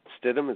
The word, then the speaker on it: Stidham: You know, I expect him to be, is it Jarrett Stidham? Is that how you pronounce his name? – Correct.